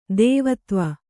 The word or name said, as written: ದೇವದೇವ